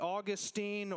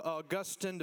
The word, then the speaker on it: Augustine: So today, brand new series on a very old sermon in the 4th century, Augustine or Augustine, depending how you want to pronounce his name, by my studies, was the first one to actually call this sermon the Sermon on the Mount.